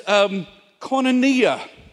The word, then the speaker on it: koinonia: It's actually a Greek word that is koinonia. Koinonia, probably the only Greek word that I can correctly pronounce.